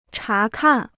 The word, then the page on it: 查看: chá kàn